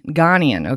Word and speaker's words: Ghanaian: Ghanaian.